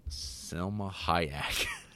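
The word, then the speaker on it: Salma Hayek: Selma Hayek. – Do you like the pronunciation on that? – You really, you killed it.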